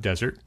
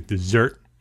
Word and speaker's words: Desert: He's based up in Maine in Mount Desert. He has actually Desert. I always say that wrong. Mount Desert. – That's right.